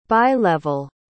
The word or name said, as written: bi-level